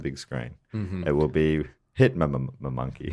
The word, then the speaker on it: Hitmonkey: And the character we're talking about is a Marvel character called Hitmonkey. Hitmonkey. Is that how they pronounce it? – That's how you pronounce it. Yeah. – Hitmonkey.